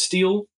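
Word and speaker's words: stele: I'm going to look up because I know that it's pronounced steel and I've heard of a steel before, but I don't know what it is.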